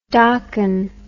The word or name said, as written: docken